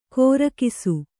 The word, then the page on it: ಕೋರಕಿಸು: kōrakisu